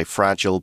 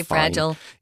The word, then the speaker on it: fragile: Now, you can say fragile, you can say fragile, both ways of pronouncing it. – I say fragile. – Yeah, fragile is a little more British pronunciation.